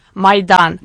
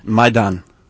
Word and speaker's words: Maidan: Maidan. – Maidan.